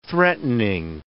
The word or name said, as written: threatening